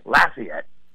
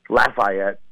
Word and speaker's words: Lafayette: Lafayette, that's how you say it, Lafayette. – Right. – But that's how we say it back in New Orleans, but there's a street in New York, Lafayette.